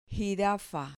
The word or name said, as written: jirafa